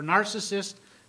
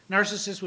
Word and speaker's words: Narcissus: There Paul invites his audience to greet those of the household of Narcissus, or Narcissus. Narcissus would be kind of like the Greek pronunciation. Narcissus would be the Latin one.